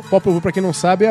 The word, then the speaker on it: Popol Vuh: A pronúncia é meio complicada, porque é o nome maia. É Popol Vuh. – Popol Vuh.